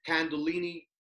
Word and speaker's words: Kundalini: you started doing this Kundalini yoga.